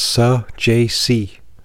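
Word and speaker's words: Saggese: I did get the definition on how to say the name. It's Sajacy. And that's from the Cardinals broadcast.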